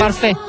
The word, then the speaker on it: parfait: ¿Se dice así? – Parfait.